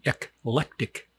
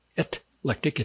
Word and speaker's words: eclectic: You got to pronounce it correctly. Ek. See, it's like people go around, they say, ek cetera. No, it's not ek cetera. It's et cetera. This is not etlectic. It's eclectic.